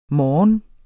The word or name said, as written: morges